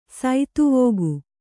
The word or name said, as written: ಸೇದೆವುಗು